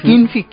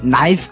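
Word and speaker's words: knife: কিন্তু যখন আপনি নাইফ কে বলবেন কিনফি আর সার্কেল কে বলবেন কিরকিল,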